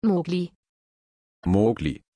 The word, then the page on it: Mowgli: German